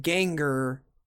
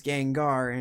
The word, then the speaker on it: Gengar: So you pronounced it Ganger, whereas it's Gangar, and you're you're, you're saying it right.